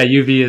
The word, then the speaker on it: uv: But so, first off, like, how do you pronounce it? – Yeah, UV is right. No, UV is right. Your first instinct was correct.